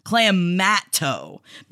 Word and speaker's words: clamato: On the east coast of Canada, we pronounce it clam, like clams in the sea, mato or mat-o, clamato, because the juice actually has clam juice in it.